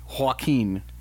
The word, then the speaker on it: Joaquin: Jaquin. Joaquin. That's how that name is pronounced.